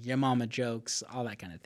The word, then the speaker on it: Yamama: but the battle was called Yamama. – Yeah. – Y-A-M-A-M-A. – Yamama.